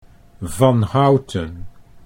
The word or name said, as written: Van Houten